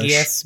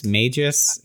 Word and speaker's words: Magus: M-A-G-U-S. I hate English. – Magius? Magus? – Yeah. I think it is meant to be Magus because it's mage.